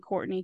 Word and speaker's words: Courtenay: John Fitz was married to a Bridget Courtenay, C-O-U-R-T-E-N-A-Y. From here on out, I'm saying Courtney because it's easier to pronounce that way.